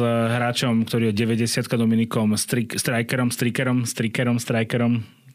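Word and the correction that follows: Stricker: Teraz by ste mali skočiť po mne, že ak sa to vyslovuje. Švajčiar, takže... Štriker.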